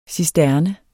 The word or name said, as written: cisterne